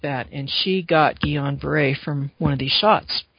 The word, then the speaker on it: Guillain-Barré: Guillain-Barré, it's spelled like Guillain-Barré, but it's pronounced Guillain-Barré.